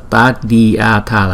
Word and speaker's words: Tadyatha: Chúng ta đọc là Tadyatha, là Tadyatha, Tadyatha. Ở chỗ này đó là Tadyatha, nhưng mà chúng ta đọc nhanh là Tadyatha, Tadyatha, Tadyatha, Tadyatha, Tadyatha.